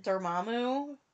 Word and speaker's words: Dormammu: Dormammu, is that it? Dormammu? – Yeah.